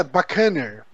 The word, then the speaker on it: Buccaneer: Aí bacana? Perguntei. E as bacana. É, porque a pronúncia deve ser diferente. Uhum. E, uh, e a é bacaner, bacaner, enfim. Uh, senti o bucaneiro retificando minha pronúncia.